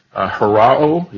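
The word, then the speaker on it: harao (ὁράω): It means harao is the way it's pronounced.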